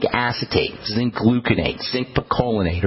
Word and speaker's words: picolinate: As it turns out, zinc acetate and zinc gluconate work similarly, but I prefer zinc picolinate. That's my favorite form of zinc as a nutritional supplement. Picolinate is spelled P-I-C-O-L-I-N-A-T-E. Picolinate. Also, I like zinc monomethionine. That second word is spelled M-O-N-O-M-E-T-H-I-O M-O-N-I-N-E.